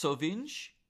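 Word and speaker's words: Sovinje: And I believe it's pronounced Sovinj. It's S O V I N J E in Croatia. And she said, The sea is incredibly clean, perfect for swimming, snorkeling, or paddling, and just simply having fun.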